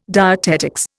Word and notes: dietetics: Indian

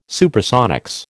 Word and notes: Supersonics: American English

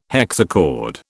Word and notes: hexachord: British English

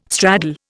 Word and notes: straddle: Indian

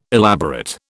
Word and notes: elaborate: British English

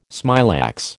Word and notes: smilax: American English

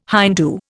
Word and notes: Hindoo: Indian

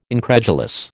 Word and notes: incredulous: American English

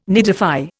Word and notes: nidify: Indian

